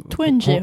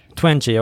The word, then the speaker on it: Twenge: Och här finns exempelvis då en amerikansk psykolog och psykologiforskare som har investerat väldigt tungt i aktien skärmalarmism, och hon heter Jean Twenge, tror jag att det uttalas. – Twenge har jag hört. – Twenge, okej.